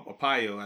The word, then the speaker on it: Arpaio: And basically, he made the parallel between Ackal and Joe or uh, Arpaio, I think that's how you pronounce his name, the former sheriff in Maricopa County in Arizona.